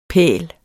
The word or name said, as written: pæl